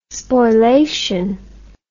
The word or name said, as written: spoilation